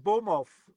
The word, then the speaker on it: Bournemouth: Also ausgesprochen Bomov.